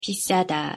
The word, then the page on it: 비싸다: Korean